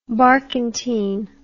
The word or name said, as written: barkantine